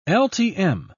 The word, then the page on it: ltm: British English